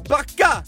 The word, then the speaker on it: backa: Det är någonting med A-et där. Jag gör backa! Du gör backa! Det är där du ska lägga betoningen på.